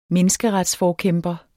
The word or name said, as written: menneskeretsforkæmper